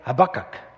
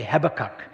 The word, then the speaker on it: Habakkuk: Firstly, we look at Habakkuk, and I think Americans say Habakkuk. Is that right? Well, where I come from, we say Habakkuk.